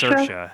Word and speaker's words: Saoirse: Saoirse, yeah.